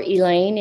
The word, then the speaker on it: Elaine: Well, you would search for Elaine, and it's E-L-A-I-N-E.